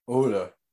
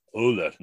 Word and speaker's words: Ohler: So how do you pronounce his name? O-H-L-E-R. Norman Uhler. O-H-L-E-R. – Uhler. – Uller, Norman Uller.